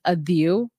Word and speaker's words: Adhieu: So my name is pronounced Adhieu,